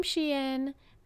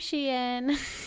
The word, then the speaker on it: Shein: My favorite part is people trying to pronounce it differently. – Yeah. Sheen. Sheen.